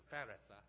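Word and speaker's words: Perez: Peres 有時讀作 Ferris，法勒斯。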